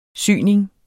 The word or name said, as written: syning